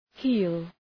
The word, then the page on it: heel: hi:l